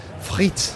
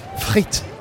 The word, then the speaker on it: frites: Or frites in French. I can't. It's difficult to pronounce the R sound in French. Frites. Frites.